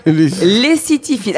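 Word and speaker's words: lécythiphile: Lessitifile. Alors attention, ça s'écrit L-E-C-Y-T-H-I-P-H-I.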